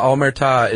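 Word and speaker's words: Omertà: I did look up how to pronounce some of these, actually. The Omerta is the code of silence. – Really? That's how you pronounce it? – Yeah, ta is the emphasis is on ta.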